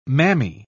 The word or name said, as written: mammy